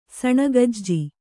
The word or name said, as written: ಸಣಗಜ್ಜಿ